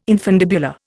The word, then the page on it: infundibular: Indian